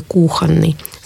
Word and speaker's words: кухонный: То есть нужно запомнить, что в этих словах ударение падает на первый слог, и в прилагательных, от которых они образованы, тоже будет падать на первый слог. Сливовый, кухонный.